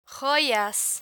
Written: joyas

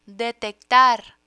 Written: detectar

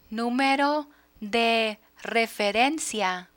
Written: numero de referencia

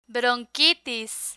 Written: bronquitis

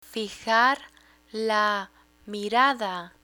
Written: fijar la mirada

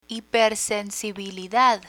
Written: hipersensibilidad

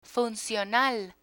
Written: funcional

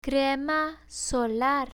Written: crema solar

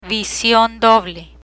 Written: vision doble

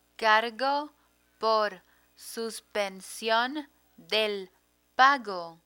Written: cargo por suspension del pago

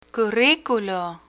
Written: curriculo

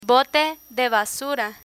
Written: bote de basura

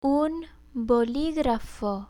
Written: un boligrafo

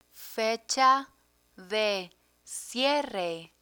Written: fecha de cierre

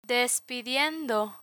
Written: despidiendo

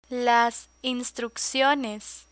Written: instrucciones

